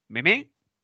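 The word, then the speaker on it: Memé: Meme. Sí, Con acento en la última E.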